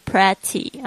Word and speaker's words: pretty: 如果她是清新漂亮型，你可以说她是 pretty， P-R-E。 T-T-Y，注意一下不要念成 pretty 啊，因为它虽然是 E，但是呢它这边是发这个小 I 的这个声音 pretty， pretty 就是漂亮的。